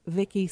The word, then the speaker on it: Vicki: And you can also find me on Twitter at Vicki St. Clair.